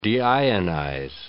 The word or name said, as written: deionize